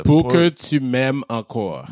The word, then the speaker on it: Pour que tu m'aimes encore: It's a pour que tu m'aimes encore.